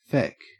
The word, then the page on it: feck: Australian English